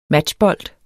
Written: matchbold

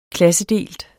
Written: klassedelt

